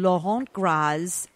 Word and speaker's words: Laurent Gras: Taste test Mitchell Davis and Laurent Gras' Pigs in a Blanket? – I think you get $1 million just for pronouncing Laurent Gras' name so well.